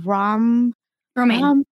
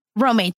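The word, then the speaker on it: Romijn: How do you say her last name? – Romaine. – Romaine, thank you.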